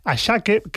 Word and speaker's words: Asake: Por cierto, el tema de los nombres Ashake, que se escribe Asake,